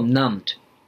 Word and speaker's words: numbed: で、 numb っていうのは、まあ、ちょっとこう、変わった綴りの単語でですね、 be で始まって B はまあ当然発音しないんですけれども numbed まあなんかこう、